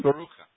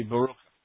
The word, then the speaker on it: Baruch: Hebrew, if you look at your name phonetically, it's mm-hmm. – Baruch. It's the your name pronounced in Hebrew phonetically off of your spelling would be Baruch.